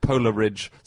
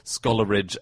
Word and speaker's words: Coleridge: And in his own poems, he rhymes it with Polar Ridge, Scholar Ridge, and The Whole Ridge.